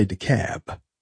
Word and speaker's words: DeKalb: Now in Georgia, they'd say DeKalb.